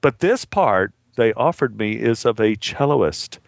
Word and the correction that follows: cellist: I don't know if that's how you pronounce cellist, Steven. – Oh. And you know, I guess I since they offered me the part, I guess I can pronounce it anyway. Now how do you say it? what do you say? I don't want to be cellist. Cellist. – Cellist. Yeah. So you don't pronounce the O.